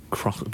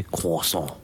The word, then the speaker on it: croissant: I keep saying croissant, but you don't say the R, do you? you say croissant. – Croissant. – Croissant. Croissant. Croissant. I'm not. – I'm not. – Croissant.